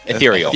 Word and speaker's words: Ethereal: And that's at Ethereal? – Ethereal. – Am I saying that right? – Ethereal. – Ethereal. Ethereal. – Right. Yeah. Ethereal.